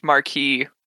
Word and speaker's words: Marquis: But it's this is French, so I'm butchering it. But Mar- Marquois probably. – Mar- I think it's Marquis. – Yeah, it's Marquis.